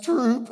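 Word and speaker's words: Troup: And I remember him driving me to several different places, you know, a place called Troop, Texas, which, yeah, it's not even spelled like you would think it's spelled. It's T-R-O-U-P-E, you know, the Texans, we got to spell things different. So Troop, Texas, Troop, as he called it.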